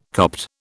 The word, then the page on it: Copt: British English